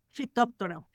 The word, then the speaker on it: Phytophthora: Phytophthora.